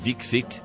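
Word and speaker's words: Dikshit: Paul Henry caused an outcry when he burst into fits of laughter while saying the name of the chief minister, pronounced Dixit, but spelled with an H in an unfortunate place for English speakers.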